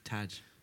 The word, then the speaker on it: Tadhg: How do you pronounce that? – Tad. Tadhg. – Yeah. – Tag. – Tadhg. – Tadhg.